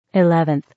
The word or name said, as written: 11th